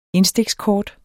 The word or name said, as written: indstikskort